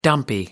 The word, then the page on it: dumpy: American English